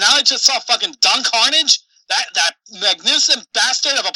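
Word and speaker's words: Don Karnage: Don Carnage. – Don Carnage.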